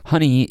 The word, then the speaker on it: Hunny: By Honey, I mean it's spelled H-U-N-N-Y, and Honey is on Epitaph Records, and they put out a fantastic record that I think fell under the radar.